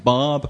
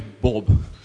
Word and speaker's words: Bob: I kept on trying to say my name with an American accent, Barb. And they thought my name was Bub, B-U-B. So I had to keep putting my name was Bob.